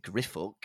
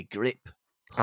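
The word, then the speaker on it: Griphook: But Marge, I'm afraid you've got to be on your own pronouncing it Griffook. It has got to be Grip Hook.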